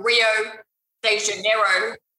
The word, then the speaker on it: Rio de Janeiro: Now, Tay, you are from Rio de Janeiro, Brazil.